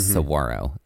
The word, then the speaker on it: Saguaro: there is a G, but that G is silent. – It's like a W. – It's like a W. Mm-hmm. Saguaro is how it's pronounced.